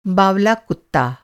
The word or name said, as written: बावला